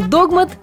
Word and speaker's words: догмат: Как же будет, догмат или догмат? Кто подумал догмат, поздравляю, вы абсолютно правы. А теперь давайте разбираться. Само слово «догмат» употребляется в значении основного положения в религиозном учении, которое принимается на веру и не подлежит критике. Догмат был заимствован из греческого языка, и ударение при заимствовании сохранилось.